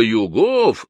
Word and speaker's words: Югов: Васька Югов! В слове «Югов» он сделал ударение на последнем слоге.